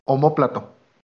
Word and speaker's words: omóplato: Ahora es omoplato. Y lo dije mal yo. – Omóplato. – Ah, entonces sí es omóplato.